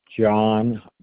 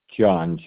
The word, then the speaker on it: John: John Broderick, and it's spelled B as in boy, R-O-D, as in dog, E-R-I-C-K. – And repeat that first name. – John, J-O-H-N.